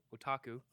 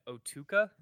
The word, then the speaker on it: otaku: Because you said what? Otuka? – I don't know. Otaku? – Yeah, it's otaku.